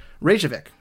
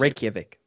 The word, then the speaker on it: Reykjavik: Reykjavik. I don't know if that's Reykjavik.